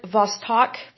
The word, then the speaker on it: Vostok: Mercury's competition um, from the Soviet Union, USSR, was the single pilot Vostok, that's how you pronounce it, a spacecraft.